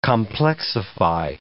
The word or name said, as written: complexify